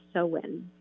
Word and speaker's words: Samhain: So the holiday actually can be traced back to the ancient Celts, who celebrated an autumn festival, and it was called Samhain, and it's spelled very interesting, S-A-M-H-A-I-N, but they pronounce it Samhain.